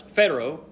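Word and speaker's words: phero (φέρω): We see this word that's used here, the Greek word, is pronounced phero, P-H-E-R-O is the phonetic spelling of it.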